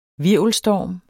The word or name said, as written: HVIRVELSTORMEN